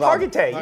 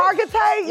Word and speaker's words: Hargitay: Hargitay, you got it. – Hargitay, you felt it coming smoothly.